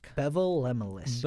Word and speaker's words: Bevel Lemelisk: So, Yeah, but so he was the guy, Bevel Lemelisk. – Bevel Lemelisk. – Bevel Lemelisk. – It sounds like the word level. – I get the word lemon in there somewhere.